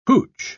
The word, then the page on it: hootch: British English